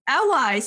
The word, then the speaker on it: Auwai: I'm super excited to be introducing one of our preferred referral partners, Tamika Auwai, right? Did I say that right now? – Auwai. – Auwai.